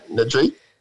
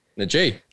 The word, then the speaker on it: Najee: That's how you uh, pronounce it, yeah? Najee. Najee. – That is exactly how you, right. that is how you pronounce it.